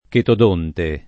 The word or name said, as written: chetodonte